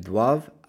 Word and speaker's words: doivent: The ENT on ILS, DOIVENT, is silent, so you end it on the V.